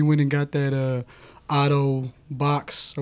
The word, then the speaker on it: Baox: It's we were told it's box, but it's spelled B-A-O-X.